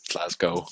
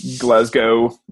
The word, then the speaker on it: Glasgow: So for those of you, W is silent. It's Glasgow. – Glasgow.